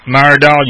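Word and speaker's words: Myrdal: Well, Myerdal, you said it right.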